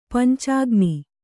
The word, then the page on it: ಪಂಚಾಗ್ನಿ: kannada